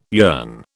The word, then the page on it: yearn: British English